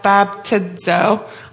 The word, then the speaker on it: baptizo: The word baptizo in Greek, pronounced as baptizo, I love Google, means to dip or immerse into water.